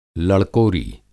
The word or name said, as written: लड़कोरी